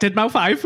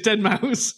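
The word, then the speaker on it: deadmau5: He's like, Dead Mouse 5, but it's Dead Mouse.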